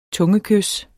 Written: tungekys

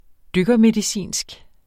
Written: dykkermedicinsk

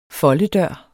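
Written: FOLDEDØR

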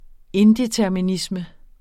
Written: indeterminisme

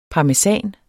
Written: parmesan

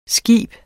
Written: skib

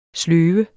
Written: sløve

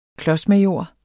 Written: klodsmajor

